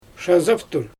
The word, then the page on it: ʃazaptur: Xyzyl